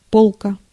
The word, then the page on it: полка: /ˈpolkə/; polka